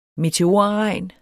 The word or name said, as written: meteorregn